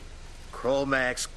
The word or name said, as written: Kromaggs